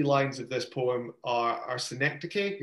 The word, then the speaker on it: synecdoche: It's pronounced synecdoche, which we only found out when we were quite young teachers.